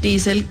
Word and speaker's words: Diesel: Diesel, eso ya se los había contado a ustedes aquí, viene del nombre de Rudolf Diesel, el ingeniero alemán que inventó el motor diesel a finales del siglo XIX. Y en alemán se pronuncia diesel como con una I larga.